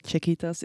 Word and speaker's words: Czechitas: Am I pronouncing it right? Czechitas, it's perfect.